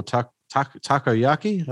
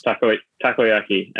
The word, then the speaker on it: takoyaki: I've got campfire mussels, coastal okonomiyaki, lobster oil, octopus dumplings, or takoyaki. I think it's takoyaki and okonomiyaki.